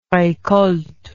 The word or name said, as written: recolte